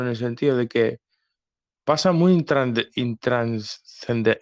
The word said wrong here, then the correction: intrascendente: es intrascendente, perdón, no sé si lo pronuncio bien, ¿eh?